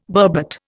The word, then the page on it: burbot: Indian